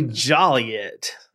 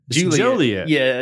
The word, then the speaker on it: Joliet: Mispronouncing the name in Juliet is a misdemeanor. So it's actually Joliet. – Yeah, Joliet. – But if you say Juliet.